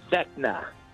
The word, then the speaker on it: Vecna: I believe I'm pronouncing this correctly. Vecna, the Mind Player, or the Mind Flayer, or Demogorgon? – It's Vecna.